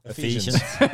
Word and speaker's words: Ephesians: And Ephesians, is that how you say it? – Ephesians.